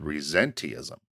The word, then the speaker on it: resenteeism: So look for the latest buzzword in corporate America right now called resenteeism. – There you go. You nailed that. – Resenteeism. R-E-S-E-N-T-E-E-I-S-M.